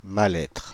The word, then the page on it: mal-être: [ma.l‿ɛtʁ]; /ma.l‿ɛtʁ/